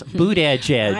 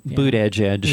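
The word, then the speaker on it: Buttigieg: Boot Edge Edge. – I can't pronounce his name. I don't like him anyway. So, yeah. Boot Edge Edge.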